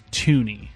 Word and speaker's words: Thuney: Pro Football Reference says the pronunciation for it is Thuney.